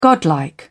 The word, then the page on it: godlike: British English